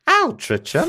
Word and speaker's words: Altrincham: Altrincham would be pronounced differently because it's English. Altrincham.